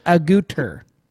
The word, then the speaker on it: Agutter: Jenny Agutter. Agutter? – Very pretty. – I'm assuming that's how you pronounce it. A gutter. A-G-U-T-T-E-R.